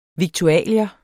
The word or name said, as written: viktualier